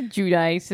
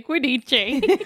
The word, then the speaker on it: Giudice: Teresa Giudice is how she pronounces it. I, a non-Italian, will say Giudice.